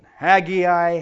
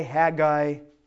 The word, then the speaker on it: Haggai: So I go back and forth just to make sure I can relate to all the way you guys pronounce it. Haggai, Haggai.